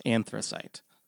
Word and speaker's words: anthracite: I said it wrong. It's anthracite.